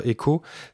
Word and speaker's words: EQQO: Euh, si vous cherchez le jeu euh, Echo, ça s'écrit E-Q-Q-O.